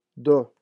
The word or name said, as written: deux